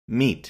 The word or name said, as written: meat